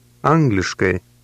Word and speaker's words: Angliškai: Pay special attention to the ending of that word. It resembles the ending of the English word hey. How do you say English? – Angliškai.